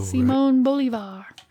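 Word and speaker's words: Bolívar: Is that how you say that? – I think so. Yeah, there's the accent on the O. – Simon Bolivar.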